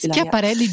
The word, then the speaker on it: Schiaparelli: C'est fait avec la maison de couture Schiaparelli, que je prononce presque juste. – Schiaparelli, giusto, brava, c'est.